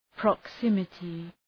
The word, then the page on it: proximity: prɒk’sımətı